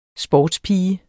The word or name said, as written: sportspige